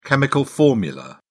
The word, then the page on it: chemical formula: British English